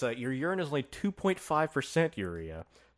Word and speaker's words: urea: And something called, if I'm pronouncing it correctly, it's four letters, so hopefully I am, urea, U-R-E-A. Urea. – That sounds about right.